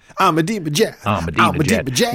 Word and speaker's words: Ahmadinejad: Ahmadinejad.